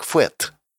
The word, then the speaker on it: Фэд: При произнесении вслух буква «Д» на конце оглушается, так что название читается как «Фэт».